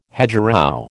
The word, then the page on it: hedgerow: American English